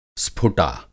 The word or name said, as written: स्फुटा